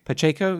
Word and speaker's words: Pacheco: Pa- I'm gonna, I always pronounce his name wrong. Uh, Pacheco, I think. – Pacheco, yeah.